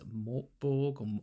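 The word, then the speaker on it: Mörk Borg: So I looked at Mokbore, is how you're supposed to pronounce it, and Pirates Bore came out while we were developing our ideas, which is a pirate-based version of that.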